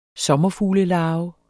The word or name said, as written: sommerfuglelarve